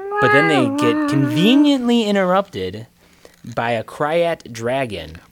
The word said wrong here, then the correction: Krayt: Or a Krayt dragon. – I don't know how you actually pronounce it. – It's Krayt. – Is it Krayt? – Yes.